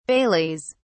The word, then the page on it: baileys: British English